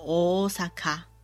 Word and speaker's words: 大阪: 大阪这个地方念作 Osaka，在日文里面它有两个 O。可是两个 O 放在一起呢，把它拉长音就好了。我们不用特别念 O O 萨卡，这样很奇怪。